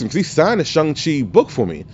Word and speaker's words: Shang-Chi: I asked him, I said, how do you pronounce his name? He was like, it's Shang-Chi. He's like, he says, I have no clue why people call it Shang-Chi.